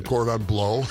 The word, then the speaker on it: cordon bleu: It is said, cordon bleu. – Well, cordon Seems bleu, odd. Please. It's like the first time you've gone to the restaurant. Could I have some cordon bleu? – Sorry, sir.